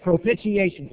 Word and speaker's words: propitiation: Propitiation. That's how you say it. Propitiation.